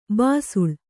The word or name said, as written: ಬಿಸಿಲ್